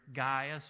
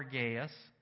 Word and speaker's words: Gaius: This man's name was Gaius, pronounced either Gaius or Gaius.